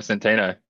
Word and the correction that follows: Centineo: if we've gone to the effort to get it right, Centineo.